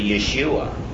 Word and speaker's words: Yeshua: So what we did was, we took, when at a point where certain kinds of Jews were pronouncing their Y's like J's, okay, even Jesus is really Yeshua, but we call it Joshua.